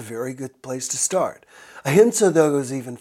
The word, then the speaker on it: ahimsa: It's pronounced ahimsa.